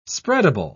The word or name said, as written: spreadable